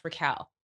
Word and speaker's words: Raquel: And the reason my nickname was Rocky was because no one could pronounce Raquel.